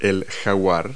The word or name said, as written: jaguar